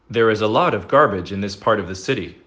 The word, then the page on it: garbage: American English